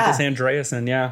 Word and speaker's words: Pontus Andreasen: Hopefully I'm saying that correctly. – I said it this morning. Yeah. Pontus Andreasen, Andreasen, there you go.